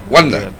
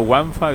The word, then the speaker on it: Wanda: Wanda. – Dice Wanda con W, así se pronuncia. Wanda, no Banda, no Wanfa, dice no Wanfa.